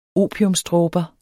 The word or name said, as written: opiumsdråber